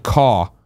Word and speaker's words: Koch: Lord of Pwn says, Koch Media is pronounced like caw with a phlegmy bit at the end. So it's a soft CH.